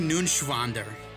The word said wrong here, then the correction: Neuenschwander: You didn't pronounce it right. Anthony? – Anthony Neuenschwander. – It's N-E-U-E-N. It's Neuenschwander. Anthony Neuenschwander.